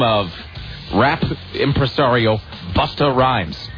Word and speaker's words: Busta Rhymes: And I swear to God, there was a long, involved, very serious discussion about whether it was Buster Rhymes or whether it was Busta. Which then, and it climaxed with the chief of the director of engineering here. The director of engineering at CBS Radio's. Sort of just a really big white guy himself standing up and saying, no, it is pronounced Busta Rhymes.